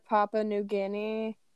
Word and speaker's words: Papua New Guinea: Madagascar, Papua New Guinea...